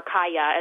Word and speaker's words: Kaja: So the scene in this book where Kaja, or Kaya as it's pronounced in Czech,